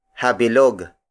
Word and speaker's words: habilog: The next word is habilog. Habilog. Habilog. Habilog is spelled as H-A-B-I-L-O-G.